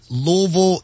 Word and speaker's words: Louisville: Y'all aren't saying Louisville or Louisville. It's Louisville, and I'm so happy and so thankful that y'all have said it correctly.